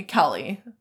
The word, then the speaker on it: Kelley: Kaylee F. – Kelly. – From Chicago. – Why do you not know how to pronounce these names? They're very easy. Kelly is spelled K-E-L-L-E-Y. Very easy. Kelly.